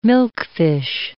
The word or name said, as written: milkfish